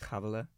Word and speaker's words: Havila: My birth name is Havila, and so that's Hebrew, and it's pronounced weird. It's pronounced Havila.